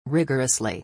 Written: rigorously

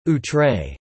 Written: outre

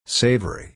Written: savory

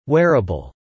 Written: wearable

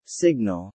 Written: signal

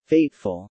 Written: fateful

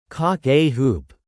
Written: cock-a-hoop

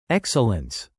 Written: excellence

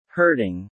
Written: hurting

